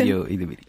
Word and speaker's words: Ayo Edebiri: PJ, Rachel Zenoth y Josie Ayo Edebiri. Bueno, ahí el nombre dudo de la pronunciación. – Ayo Edebiri, sí.